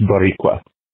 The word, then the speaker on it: Boricua: It's pronounced Boricua. Huh? Boricua. That's what Puerto Ricans call themselves. The island is called Borican. The people are called Boricua.